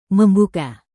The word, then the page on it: membuka: Indonesian